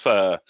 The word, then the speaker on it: pho: It's spelt pho, but it's actually pronounced pho.